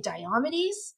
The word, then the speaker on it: Diomedes: There's two different ways of pronouncing it, but I say Diomedes.